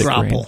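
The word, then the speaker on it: graupel: It's grapple.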